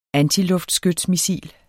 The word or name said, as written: antiskating